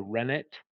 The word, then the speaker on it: rennet: It can be, usually the most common is a rennet. Now it's spelled R-E-N-N-E-T, and it's one of the few times where a French word you actually pronounce the T.